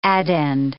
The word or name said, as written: addend